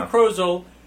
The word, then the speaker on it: Krozel: Joe Krozel, rhymes with puzzle, also did the vowel puzzle.